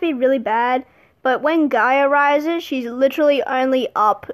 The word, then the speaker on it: Gaia: Whereas in Heroes of Olympus, we have, depending on where you are, the pronunciation is either Gia or Gaia. I personally pronounce it as Gaia.